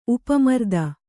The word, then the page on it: ಉಪಮರ್ದನ: Kannada